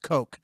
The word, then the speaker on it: Koch: The person selling the cocaine to the Pirates was the Pirate Parrot mascot, whose name was Kevin, and his last name was K-O-C-H, pronounced Coke.